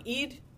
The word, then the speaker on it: Eid: Eid.